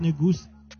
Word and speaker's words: Negus: He says it's pronounced Negus. As in with a goose.